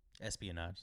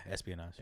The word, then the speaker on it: espionage: Espin. – What, what is it? – E S. This one. – Espionage. – Espionage. Being, being spy uh, espionage. Did I say that right? – Yeah, espionage. Espionage. Okay.